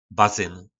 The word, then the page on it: bazyn: po nasimu